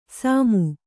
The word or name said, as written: ಸಾಮು